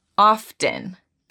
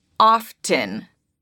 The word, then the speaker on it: often: La palabra often puede ser pronunciada de dos formas. Una es diciendo con la F. Y la T, often. Often. Y otra forma es sin la T, often. Often. Ambas son correctas y puedes usar la que tú quieras.